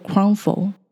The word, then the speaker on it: Croffle: 可颂松饼的英文名字我觉得不太好念，念作 crumble。它的拼法呢是 C R O F F L E，是用 croissant 的前面的 C R O 跟 waffle 的后面的 F F L E 结合起来的新创字。